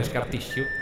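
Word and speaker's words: Scar Tissue: L'ho detto bene, Scartissio. – Dici sempre bene.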